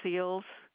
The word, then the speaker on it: Seals: It's Treva Seals. – Spell that for me. – T-R-E-V-A. Seals, S-E-A-L-S, like a Navy SEAL. – S-E-A-L-S, correct, ma'am? – S, like Sam, E-A-L-S.